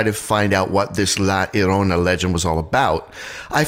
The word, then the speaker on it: La Llorona: I had always pronounced it La Llorona. I had pronounced the L's, but apparently I was being very sort of Anglo-Saxon about that. It's La Llorona.